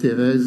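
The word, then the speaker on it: Thérèse: So Teresa is the English pronunciation, but Therese is the French.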